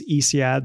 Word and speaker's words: Ysiad: I mean, my name's Isiad. That's spelled Y-S-I-A-D.